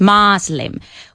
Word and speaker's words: Muslim: Con una A de estas abiertas. Muslim.